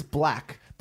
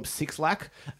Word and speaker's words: 6LACK: His name is Black, but it's spelled 6LACK. So I always call him 6LACK.